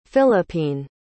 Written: Philippine

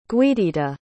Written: guiditta

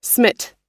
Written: smit

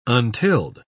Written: untilled

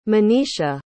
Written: manisha

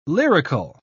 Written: lyrical

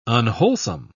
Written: unwholesome

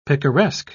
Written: picaresque

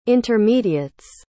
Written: intermediates

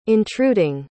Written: intruding

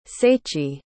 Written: seiichi

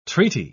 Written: treaty

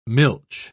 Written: milch

